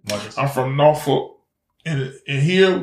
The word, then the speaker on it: Norfolk: It's Norfolk. That's how we say it. We country. We got country, little country twang, you know. And right. It's Norfolk. So even though it looked like it's spelled, it's really spelled N-A-W.